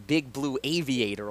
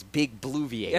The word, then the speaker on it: Big Blue Viator: Is this I called him Big Blue Aviator all the time, but it's Big Blue Viator.